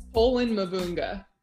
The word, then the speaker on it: Pohlen-Mavunga: Pohlen-Mavunga. – Now we're Pohlen-Mavunga. – Mavunga. Yeah. Long U sound.